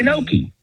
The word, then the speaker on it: gnocchi: And I do know that in Lazio and in Tuscany, it's pronounced Gnocchi.